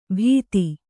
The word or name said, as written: ಬಿತ್ತಿ